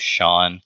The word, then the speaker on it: Sean: And so, my name's Sean. It's pronounced S-E-A-N, or it's spelled S-E-A-N. You could pronounce it that way, yeah, too, if you want. But a lot of, some people get a little confused on how to pronounce it.